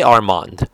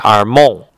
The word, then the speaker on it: Armand: Reb Rafael says, but you know, in French, they don't say Armand. They say Armand, right? And therefore, halachically, you should write the name as Aleph Resh Mem Vav Nun, because that Dalet, that D sound, is silent in the French.